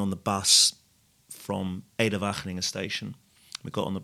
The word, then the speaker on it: Wageningen: She lives in Wageningen, which is wherever it is. – You said that beautifully, by the way. Well, I do have good pronunciation, I've been told. Wageningen.